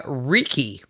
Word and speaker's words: Ricci: We're talking to Monica Ricci, right? Did I say that right this time? R-I-C-C-I.